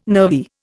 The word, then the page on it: nervy: Indian